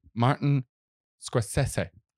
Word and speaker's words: Scorsese: that his name is pronounced Martin Scorsese.